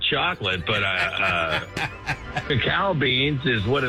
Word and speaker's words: cacao: So is that cacao beans? Because I don't think it's cocoa. – Yeah, I've always heard cacao. – Cacao. It's spelled different, though. It's spelled C-A-C-A-O.